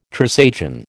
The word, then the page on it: Trisagion: American English